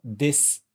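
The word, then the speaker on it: des (です): Porque quando a gente fala, por exemplo, des, na verdade a gente escreve com S, U no nosso alfabeto, mas a pronúncia a gente não fala esse U, né? Então a gente fala des, como se tivesse só o S, e a gente não fala, não pronuncia a vogal U.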